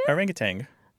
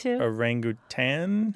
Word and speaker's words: orangutan: I grew up saying it incorrectly. – Did you? – Orangutan. As if there was a G at the end.